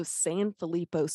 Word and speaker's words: Sanfilippo: So she posts a lot of social media about Sadie, which is her daughter, and she has a diagnosis of Sanfilippo syndrome. Is that how you pronounce it? – That's right. That's good.